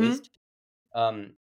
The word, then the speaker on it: Wiest: The aunts, Stalker Channing and Diane Wiest. Is that how you say it? – Mm-hmm. – Wiest.